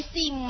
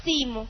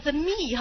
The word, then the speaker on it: theme: 这就是那 theme 吗？主题，这才是我要表示的精神，是不是啊？ theme 看到了哈，这结构读的时候别读成 the me 哈，人家不知道你说什么，对吧？记得哈， theme 哈，记得。